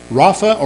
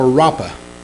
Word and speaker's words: Rapha: That's the title, Yahweh, Y-A-H-W-E-H, Rapha. Now let's pronounce either Rapha or Rapha. It's pronounced both ways. It's R-A-P-H-A.